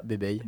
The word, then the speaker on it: Bebey: comment on le prononce comme, comme ça, Bébé.